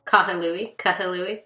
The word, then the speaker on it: Kahului: They're all right there near each other, and they aren't too far from Kahului. Is that how you say it? Which is where the airport is. – Mm-hmm. Kahului.